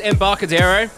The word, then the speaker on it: Embarcadero: We practice a couple of different pronunciations. I think it's Embarcadero.